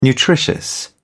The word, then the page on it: nutritious: British English